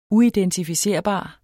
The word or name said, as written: uidentificerbar